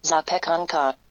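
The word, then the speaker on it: zapiekanka: Also hier in Polen gibt es so ein Streetfood, das richtig bekannt ist, und zwar ähm, heißt es Schapiekanki. Schapiekanki. Ich äh, guck mal, ob ich es richtig ausgesprochen habe. Sapekanka. Ja, fast.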